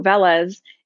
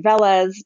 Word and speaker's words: Veles: So sometimes I say Veles, people on my team say Veles. I usually say Veles. But I allow other pronunciations.